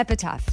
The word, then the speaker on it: epitaph: An epitaph? Epitaph? Oh. Epitaph. – So it's like when someone dies. That's what that is. – Is that how you say it? – Yeah, sure. – Yeah, cool. Epitaph. Yes.